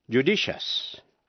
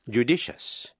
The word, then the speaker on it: judicious: Nếu trước đuôi IOUS có âm T, C hay X, thí dụ, judicious, judicious, J-U-D-I-C-I-O-U-S, judicious là sáng suốt.